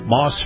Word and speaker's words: Maus: Moss Family Automotive, namely Moss Acura. Now, Moss is spelled M-A-U-S, and of course, Acura is A-C-U-R-A, for those of you who don't know.